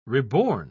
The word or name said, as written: reborn